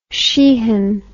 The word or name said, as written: Sheehan